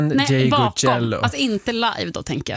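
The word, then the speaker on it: Ndegeocello: Michelle Ngeg-Joe. – Michelle. – Michelle Ngeg-Joe. – Nej, Ngeg-Ecello. Ngeg-Ecello, ja. Kanske.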